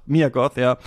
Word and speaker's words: Mia Goth: Maya Goth spielt sie ja auch noch, ne? Also es ist ja tatsächlich auch Mia Goth, spricht man sie so aus, ja.